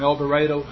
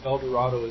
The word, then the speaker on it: El Dorado: We're from El Dorado, not pronounced El Dorado as it should be.